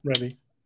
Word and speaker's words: Rebbe: And the Bitcoin Rebbe, I'm sorry if I didn't pronounce that correctly. – No, you did, Rebbe.